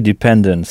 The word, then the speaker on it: dependent: Donc, en début de mot, on entend « di », qui s'écrit pourtant « de », « dependent », avec « ent » à la fin, et non « ant », comme en français.